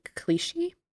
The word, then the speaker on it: Clichy: Let's start with the Friday, March 25th, New York Times by Evan Clichy. 17 across. – Kalish? – No, it is not Kalish. Oh. It's not Evan Kalish. I thought it was, but then it's spelled C-L-I-C-H-Y, I believe.